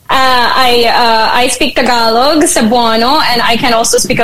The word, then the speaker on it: Cebuano: Cebuano. – Okay, so if I say, Cebuano.